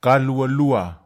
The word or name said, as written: kalwalwa